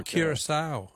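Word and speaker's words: Curaçao: And I don't know if I'm pronouncing it right, but I thought it was Curacao. – Curacao, yeah. No, that's very good. I'm sure you're pronouncing it correctly, but that's not Dutch West Indies.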